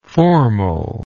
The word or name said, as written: formalness